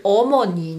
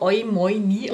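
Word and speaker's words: Omoni (어머니): Ich verstehe das wirklich, aber ich bin wirklich der Meinung, man muss so schnell wie möglich davon wegkommen, weil mit der Romanisierung kannst du zum Beispiel Omoni nicht gescheit aussprechen. Ja? Das schaut dann irgendwie so Oimoini an.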